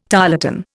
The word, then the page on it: tarlatan: Indian